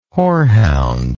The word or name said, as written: hoarhound